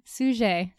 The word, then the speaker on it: Suger: Suger. It's spelled like sugar, guys. It's really cute.